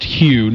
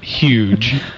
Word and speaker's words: Hugh: Yes, and it's pronounced Hugh, not huge, the way some people say it around here.